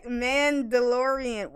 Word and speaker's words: Mandalorian: Mandalorian.